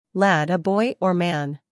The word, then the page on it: lad: læd